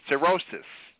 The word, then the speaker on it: cirrhosis: Number 10, cirrhosis. I think I pronounced it correctly. C-I-R-R-H-O-S-I-S.